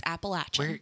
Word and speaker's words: Appalachian: I always say Appalachian. Guys, we know. Don't come for us. It's Appalachian.